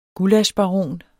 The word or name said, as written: gullaschbaron